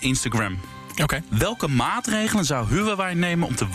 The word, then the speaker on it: Huawei: Ehm, wij als Huawei, ik zal het maar op zijn Nederlands uitspreken. Wij als Huawei in Nederland zijn een Nederlands bedrijf. Mm Huawei, want zo spreek ik het uit.